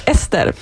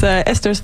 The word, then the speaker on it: Esther: Yeah, my name is Esther, and if you want to try and pronounce it the Swedish way, it's Esther, but Esther's fine.